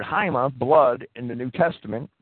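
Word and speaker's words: ahima (αἷμα): It's actually ahima in Strong's pronunciation guide. Some people might want to call it hema.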